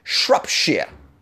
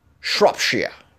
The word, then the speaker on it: Shropshire: Did I say it right? Shropshire. There's so many towns that end with shia. And you must say it like that. You must say it like Sean Connery. Shropshire.